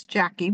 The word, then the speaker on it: Jacc: It's Jackie, but it's spelled like JC.